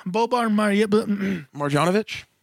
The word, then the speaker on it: Boban Marjanovic: Boban Marjanovic? – No. – Boban. – Boban Marjanovic. There we go. I said it right.